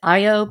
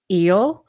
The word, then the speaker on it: Io: It's the myth of Zeus and Io, although some people pronounce it Io, but the old pronunciation is Io.